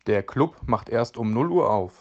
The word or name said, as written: Club